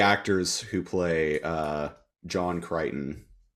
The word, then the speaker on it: Crichton: Yeah, uh, how do you pronounce like the the dude's name? John Crichton. Uh, uh, John Crichton. Yeah. Crichton. Yeah.